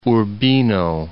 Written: Urbino

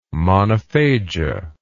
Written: monophagia

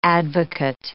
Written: advocate

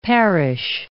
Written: parish

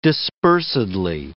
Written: dispersedly

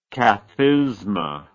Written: cathisma

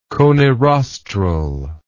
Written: conirostral

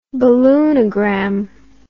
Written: balloonagram

